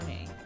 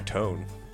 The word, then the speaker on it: Tone: And the layout is Tone Rodriguez. And he has a pretty interesting story about himself. – Is it Tone or Tony? – It goes by Tone. T-O-N-E.